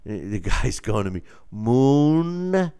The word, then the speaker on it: Munguia: I'm pronouncing it wrong again. It's Munguia, right? What did I say? Munguia? Okay, yeah, it's Munguia.